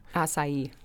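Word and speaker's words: açaí: Acai.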